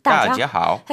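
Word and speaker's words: 따지하오 (大家好): 따지하오.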